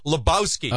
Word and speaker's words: Lebowski: These are the filmmakers behind The Big Lebowski.